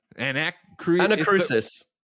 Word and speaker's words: Anacrusis: But, and I'm going to butcher this one. The Anacrusis. – Anacrusis. – Thank you.